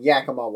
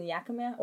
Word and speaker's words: Yakima: For reference, the ball is in Yakima, Washington.